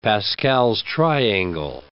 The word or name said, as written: Pascal's triangle